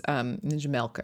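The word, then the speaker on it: Ninja Melk: But so Ninja Malk was, Ninja Milk, actually, I guess that's how you say it, M-E-L-K, was founded by Ryan Higa and also this woman, Kathleen Hahn.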